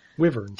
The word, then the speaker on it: wyvern: Google pronunciation says it's wyvern.